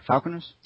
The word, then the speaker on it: Falconer: With Bruce Falconer's, is that correct? Falconer's? – Well, I suppose it's wrong, but that's correct pronunciation.